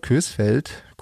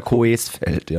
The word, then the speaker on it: Coesfeld: Naja, also, und ich, ich kann mich noch erinnern, äh, die, die erste Show war, glaube ich, in Coesfeld oder Kösfeld, Coesfeld. – genau, es, heißt sicher Coesfeld, ja.